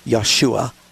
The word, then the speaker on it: Yeshua: And so that's why the angel said, you'll call his name Yeshua, for he will save his people from their sins. And so if you want to be more literal, that's the way Jesus' name is pronounced, and his name means Jehovah is Savior.